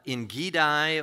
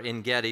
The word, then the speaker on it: Engedi: He had run to the caves of Engedi or Engedi, depending on, and it's pronounced both ways.